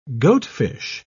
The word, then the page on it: goatfish: British English